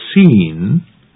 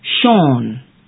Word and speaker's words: Sean: The name is spelled S-E-A-N. – S-E-A-N. The pronunciation is not seen, but Sean.